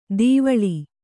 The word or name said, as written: ದೀವಳಿ